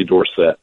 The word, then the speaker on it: Dorsett: who ran at Pitt but added an E to his name later and changed the pronunciation of his last name to Tony Dorsett.